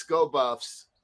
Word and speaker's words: Sko Buffs: So, I got to say it, top story of the week, Coach Prime, go Buffs. Well, I said that wrong. It's Sko Buffs. So if you live in Colorado, you know what I'm saying.